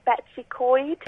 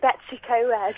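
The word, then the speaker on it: Betws-y-Coed: my friend told me how to pronounce this because I was pronouncing it wrong. Betsy Coyd. – Betsy Coyd. Betsy Coyd. – Exactly. And I've been saying Betsy Coed.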